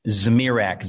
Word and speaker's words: Zmirak: and they never pronounce Zmirak correctly. That's a moment for me to say it's Zmirak, Z-M-I-R-A-K.